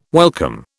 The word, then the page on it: welcome: British English